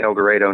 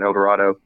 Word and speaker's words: El Dorado: And Kentucky fans can probably, Kentucky natives can probably appreciate the pronunciation, El Dorado, not El Dorado.